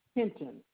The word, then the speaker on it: Hinton: Joyce Hinton, H-I-N-T-O-N. – Spell that last name for me. – H-I-N-T-O-N.